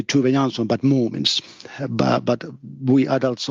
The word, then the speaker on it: Tove Jansson: Yes, actually, her name is pronounced Tove Jansson. That's Swedish name, and it's pronounced a bit different than Finnish name, but Tove Jansson.